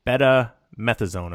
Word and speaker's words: betamethasone: came back with a positive test for betamethasone.